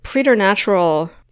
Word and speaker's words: preternatural: We think intuition is some kind of almost like preternatural knowing. I just had to look that word up because I'm like, is that a word? And did I pronounce it correctly?